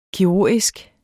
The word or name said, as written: KIRURGISK